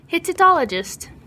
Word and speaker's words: Hittitologist: Hittitologist.